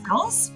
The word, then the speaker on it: Taos: And so Susan is a domestic adoptee, former foster child of the baby scoop era, originally from Canada, but now living in, Taos? Is that how you say it? – Yes. – Taos, New Mexico.